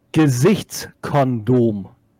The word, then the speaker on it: Gesichtskondom: Gesichtskondom. – Gesichtskondom? – No, the G is hard. As in what I said before, as in Genesis.